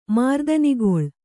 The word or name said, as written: ಮಾರ್ದನಿಗೊಳ್